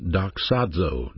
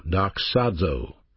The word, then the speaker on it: doxazo: So glory is a noun, glorify is a verb, and glorify is pronounced doxazo, doxazo.